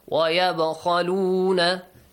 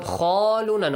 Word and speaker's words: وَيَبْخَلُونَ: Yab khaluna Now the letter kha. – It's pronounced heavily. – Heavily. It's a heavy letter. وَيَبْخَلُونَ. Not خَالُونَ, no. Because it doesn't have an alif.